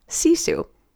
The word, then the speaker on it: Sisu: It's about digging down into that deep, deep place and accessing what I've learned to call Sisu. It's spelled S-I-S-U, and it's something we all have, but I just recently discovered its power and its purpose.